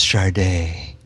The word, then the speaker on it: Sade: It was like spelled Sade. – Right, It's pronounced Sade.